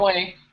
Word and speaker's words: Huawei: Huawei, did I pronounce that right? Huawei, yeah. – Huawei.